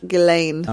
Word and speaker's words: Ghislaine: Ghislaine is what I've heard. Ghislaine.